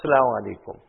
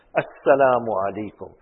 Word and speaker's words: Assalamu Alaikum: One Sudani man told our Hadrat in Karachi, he said that salam was being made and the Hamza, the Alif at the start must be made clear, pronounced clearly. Not Salaamu Alaikum. Assalamu Alaikum.